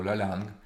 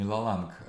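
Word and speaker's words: la langue: Есть язык как структура, Сасюр называет его ля-лянг, это структура, ля-лянг, раздельно, это не ла-ланг, а лакан.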